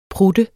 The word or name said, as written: prutte